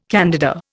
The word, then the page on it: candida: Indian